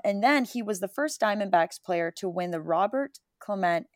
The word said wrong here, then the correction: Clemente: and I think I pronounced his last name correctly. Roberto Clemente, excuse me.